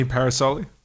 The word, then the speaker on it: Parasole: One of the first things that people ask is how do you properly say your last name? – That's a good question. It's Anthony Parasole.